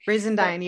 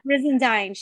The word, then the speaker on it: Brizendine: I loved when we had our guests, you know, and we were able to talk to our guest, Dr. Luann Brizendine. I hope I said her name right. – Brizendine. Yeah. – Brizendine.